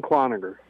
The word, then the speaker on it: Cloninger: I first off, though, have to ask you, did I pronounce your last name correctly? – Alan Cloninger. – Cloninger. Because, like, there's three ways to pronounce it.